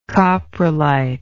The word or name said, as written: coprolite